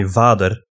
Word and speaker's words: Vader: I mean, spelled the same way, but pronounced differently. We say Vader.